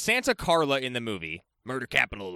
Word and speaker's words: Santa Carla: Booming around Santa Carla? I will say it wrong at least once in this episode. – Yeah, it's not Santa Clarita. – Not that either.